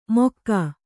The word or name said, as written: ಮೊಕ್ಕಾ